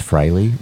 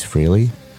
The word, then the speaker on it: Frehley: And Australians, it is Ace Frehley, not Ace Frehley.